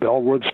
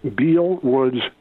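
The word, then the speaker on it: Beall Woods: And if you're looking for information on Bellwoods State Park, the pronunciation, B-E-A-L, L looks like Beale, and everybody who is unfamiliar with the site probably says Beale Woods State Park. It's actually pronounced Bell Woods State Park, as if it was B-E-L-L.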